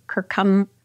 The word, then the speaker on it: curcumin: The curcumin.